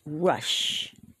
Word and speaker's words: resh: The next one is rush, like in run. R-E-S-H. The next one is shin, like in ship. S-H-I-N.